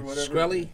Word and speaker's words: Shkreli: Shkreli? – Yeah. Shkreli.